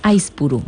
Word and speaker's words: Aizpuru: Aizpuru, vale. – Con la Z se pronuncia como S.